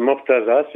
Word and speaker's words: Mortaza: Si, si, c'est Mortaza, c'est M-O-R-T-A-Z-A.